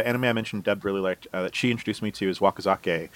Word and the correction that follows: Wakakozake: Wakakozake. – Wakako. – Zake is like sake. So Wakako and her sake.